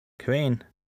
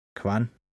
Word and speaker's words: kvan: Kvan, Kvan eller Kvan man kan ifølge ordbogen udtale det på tre forskellige måder.